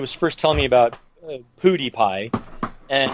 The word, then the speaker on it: PewDiePie: It's PewDiePie.